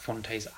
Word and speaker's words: Phonte: Not a bad thing to say about Fonte's album at all. So make sure you guys check that out. That's Fonte, that's spelled P-H-O-N-T-E, and the album's called No News Is Good News.